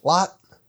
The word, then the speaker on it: Lot: Do we have an official pronunciation on that? – Lot? Yep. – Like he throws a lot of discs.